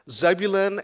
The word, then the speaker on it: Zebulun: Zebulun and Naphtali.